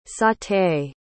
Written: sauté

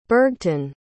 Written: burgtun

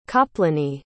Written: kaplony

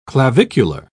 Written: clavicular

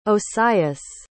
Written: osias

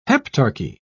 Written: heptarchy

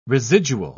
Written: residual

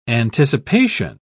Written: anticipation

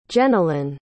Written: jennalyn

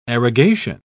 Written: arrogation

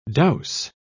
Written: douse

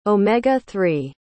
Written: omega-3

